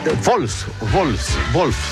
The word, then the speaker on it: Wolfsburg: No, ese Wolfsburg, porque la W se pronuncia como V corta, ¿no? O V, ¿no? Se dice V.